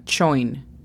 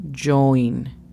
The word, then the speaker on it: join: La pronunciación fonética es casi como la CH o I-N, pero no tan duro. Con CH sería join, un poquito más suave, entonces join.